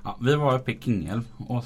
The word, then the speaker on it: Kungälv: Man, ni som inte bor här tror att man säger Kungälv. Men man, men man ska uttala Kungälv som ett, ett tyskt U. Med två prickar. Så det blir Kingälv.